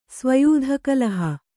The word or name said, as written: ಸ್ವಯೂಧಕಲಹ